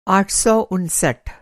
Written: आठ सौ उनसठ

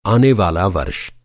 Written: आनेवाला

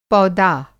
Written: पौदा

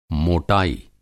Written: मोटाई

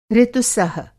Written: ऋतुसह

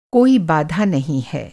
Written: बाधा

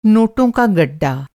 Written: गड्डा

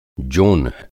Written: जोन्ह

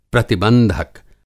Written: प्रतिबंधक

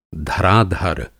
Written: धराधर

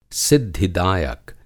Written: सिद्धिदायक

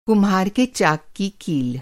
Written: कील

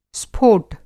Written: स्फोट

